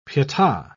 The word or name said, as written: pieta